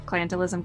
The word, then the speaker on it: clientelism: And did I pronounce clientelism correctly? – You sure did.